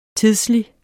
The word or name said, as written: tidslig